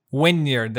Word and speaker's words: Wynyard: So his earliest days, and you mentioned, if I'm saying this right, Wynyard. Did I get that correct? – Wynyard.